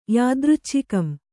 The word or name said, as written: ಯಾದೃಚ್ಛಿಕಂ